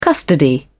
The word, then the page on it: custody: British English